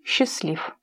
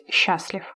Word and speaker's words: счастлив: «Он был любим, по крайней мере, так думал он, и был счастлив». В настоящее время литературной нормой является ударение на первый слог, то есть «счастлив».